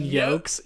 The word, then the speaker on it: yolk: remember when our buddy pronounced the L in yolks? Yolks.